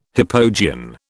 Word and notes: hypogean: British English

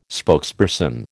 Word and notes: spokesperson: American English

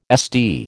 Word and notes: SD: American English

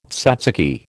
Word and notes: tzatziki: American English

